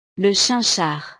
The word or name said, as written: le chinchard